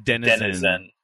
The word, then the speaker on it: denizen: Say that word again. – Denzians. – Denzians. – You have never played a role-playing game. – Why? How would you say it? – It's Denizen.